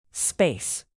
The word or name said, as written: space